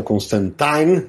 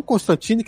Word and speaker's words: Constantine: Porque vamos lembrar, o John Constantine, que a gente fala Constantine no Brasil, mas é um personagem inglês, né? – Na pronúncia na Inglaterra, e isso tem nos quadrinhos, é cânone na revista Hellblazer. Ele até diz: meu nome é Constantine. E ele fica até puto quando outros falam Constantine.